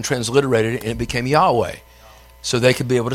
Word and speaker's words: Yahweh: It's YHWH. We pronounce that as what? Yahweh. Yahweh. It was written that way because the Jews didn't believe you should be able to pronounce the name of God, so they didn't put any vowels with it.